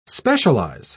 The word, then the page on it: specialise: British English